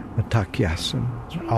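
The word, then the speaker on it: Mitákuye Oyás'iŋ: A Lakota word. I believe it's pronounced Matakyasan.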